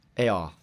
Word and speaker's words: Eyal: Eyal.